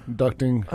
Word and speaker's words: ducting: Tropospheric ducting. Like duct tape ducting. – Okay. – So D-U-C-T.